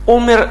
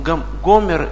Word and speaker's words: омер: Правильно сказать «омер».